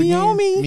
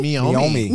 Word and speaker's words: Meiomi: Pronounce it Meiomi. – Meiomi.